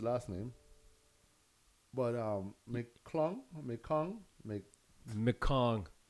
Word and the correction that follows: McClung: It's Mac McClung, man.